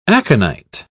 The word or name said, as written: aconite